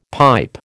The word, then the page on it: pipe: American English